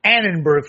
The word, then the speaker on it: Annenberg: Addenberg. Let's see that. Addenberg Group. Annenberg Foundation. They're the ones who should be under the fire here.